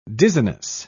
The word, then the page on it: dizziness: British English